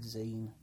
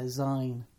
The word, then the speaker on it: zine: Yeah, and I had friends that seemed to be unable to pronounce the word zine. It was often pronounced zine. – Really? – Yeah, zine. That's weird, isn't it? Because it's not a magazine.